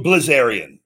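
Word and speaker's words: Bilzerian: Dan Zabarian. – Yeah. Dan's what's the damn house? I know the guy's name. – I know I'm saying the last name wrong. – Dan Blazarian, right? – Blazarian, yeah.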